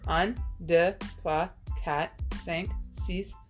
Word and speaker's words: cinq: Cinq? – It's cinq.